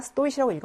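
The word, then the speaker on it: 스토이시라고 (стоит): 이렇게 하는 거고 강세가 여기에 있었기 때문에 우리가 스토이시라고 읽었는데 얘 동사 원형은 스토이지예요. 자, 예는 스타야트 어디 어디에 서 있다. 예는 스토이츠 뭐뭐와의 가치가 있다라는 말이거든요.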